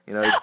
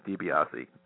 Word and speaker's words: DiBiase: Incidentally, by the way, I've heard, every pronunciation under the sun for my name, so you're just fine. It's DiBiase. – Oh, did I say it correctly? – I've heard DiBiase. I've heard DiBiase. I've heard DiBiase. To be honest, the authentic Italian way is DiBiase, and that's it's kind of funny because I'm trying to figure out how to say it, and I butcher everybody's name.